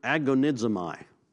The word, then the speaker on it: agonizomai: What does agonizomai sound like? agonize. Actually, it's spelled much like the word agonize with the oh my on the end, okay? Agonize, oh my, okay.